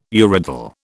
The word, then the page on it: urodele: British English